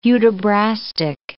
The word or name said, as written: Hudibrastic